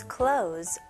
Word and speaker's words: clothes: 여기서도 똑같이 발음을 clothes 이렇게 할 필요 없습니다. Z 발음으로. His clothes got wet.